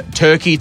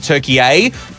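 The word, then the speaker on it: Türkiye: No, so they changed the pronunciation recently from Turkey to Turkey A. Turkey is the Western sort of pronunciation of it all, but Turkey A is the traditional way to say it, and it's been officially recognised by the United Nations since about 2022, I believe.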